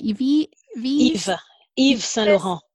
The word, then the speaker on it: Yves Saint Laurent: How do you say the first bit? – Yves Saint Laurent.